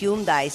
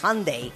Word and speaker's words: Hyundai: también tuvo que hacer una campaña para que la gente aprendiera que no es Hyundai, sino es Hyundai.